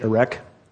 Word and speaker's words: Erech: In the Bible, it's spelled E-R-E-C-H, Erek.